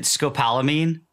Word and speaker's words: scopolamine: I don't know if I'm pronouncing it right, Kevin, but is it scopolamine? – Yeah, that's the one.